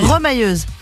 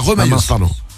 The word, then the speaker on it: remailleuse: Victoria est rémailleuse. C'est ce que c'est? – Rémailleuse. C'est ça, rapport avec les mailles. – Remailleuse. – On dit, ça se prononce pas comme ça? Remailleuse? – Remailleuse, oui. – Ah, remailleuse, pardon.